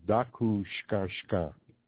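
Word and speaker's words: Taku Škaŋškaŋ: But that's the way it looks like when it's typed. But it's pronounced Dakushkanshka.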